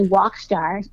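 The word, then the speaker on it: WOCstar: So the name of the company, Walkstar, which is spelled W-O-C star, is exactly that, women of color stars.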